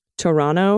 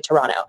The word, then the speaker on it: Toronto: You don't pronounce the second T most of the time. Most Canadians would say Toronto as opposed to Toronto.